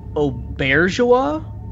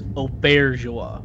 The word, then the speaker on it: Auberjonois: I feel like every time I've seen him talk about it, he pronounces it Auberjonois. – Okay. – So that's always the way I say it, is Auberjonois.